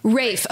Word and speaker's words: Ralph: Rafe. – Okay, so I looked it up. I watched pronunciation videos. Because it's spelled Ralph.